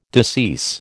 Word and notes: decease: American English

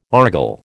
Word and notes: argol: American English

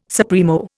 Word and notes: supremo: Indian

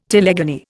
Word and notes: telegony: Indian

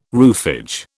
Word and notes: roofage: British English

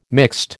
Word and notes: mixed: American English

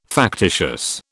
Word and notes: factitious: British English

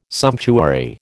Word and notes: sumptuary: American English

